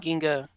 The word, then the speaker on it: Ginga: Is that how you pronounce that? Ginga? – Ginga, yeah.